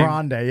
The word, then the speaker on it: Grande: Is that how you say her name? – Grande, yeah.